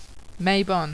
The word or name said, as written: Mabon